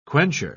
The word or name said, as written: quencher